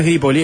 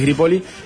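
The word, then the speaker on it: Grippoli: María Sara es Gripoli, porque no lleva tilde, no es Gripoli, es Gripoli.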